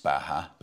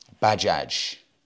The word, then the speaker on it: Bajaj: And it's not pronounced Baja because it is not a Spanish brand. It's pronounced Bajaj. And I'm hoping that's right. That may not even be right. But I had a lot of people pulling me up on this. Gorav said, it's pronounced Bajaj, like Bajaz, not Baha.